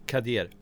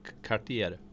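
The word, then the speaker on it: Cadier: Ja, hur, hur säger du? – Kadier. – Kadier. Ja, Kadier. Alla säger kartier. – ja. eller Kadier.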